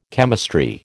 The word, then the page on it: chemistry: American English